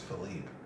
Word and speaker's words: Phillippe: My name, on my birth certificate, I don't know why, but it's spelled P-H-I-L-L-I-P-P-E. – Philippe? – Philippe? It's Philippe. So when you say Philip in Creole, it's Philippe.